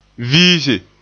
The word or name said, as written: viise